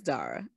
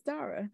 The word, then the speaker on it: Dara: So you're nicer than me, because for the people who say Dara, they saying it wrong. And so they could be as offended as they want to because they're saying their name wrong. It's Dara. It's not my fault y'all say your name wrong, but I'm just letting you know.